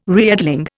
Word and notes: reedling: Indian

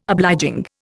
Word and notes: obliging: Indian

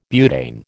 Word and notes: butane: American English

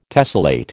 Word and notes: tessellate: American English

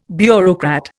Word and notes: bureaucrat: Indian

